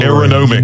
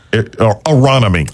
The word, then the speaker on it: Aronimink: Aronomic. – Or Uniqlo or Aero. Say it again. – Aronimink.